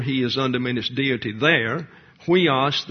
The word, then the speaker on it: huios: and so it's spelled huios, your hissing sound for the H and the U.